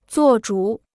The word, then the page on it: 做主: zuò zhǔ